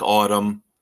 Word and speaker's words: ATUM: I know I'm not going to get the name right, even though they have said it's pronounced like autumn, spelled A-T-U-M.